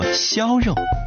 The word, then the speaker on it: 肴肉: 肴肉很多人念作肴肉，是江苏镇江的传统名菜，是淮扬菜系中的代表菜肴。开国大典，四味冷碟，周恩来总理钦定镇江肴肉，足见肴肉的美味。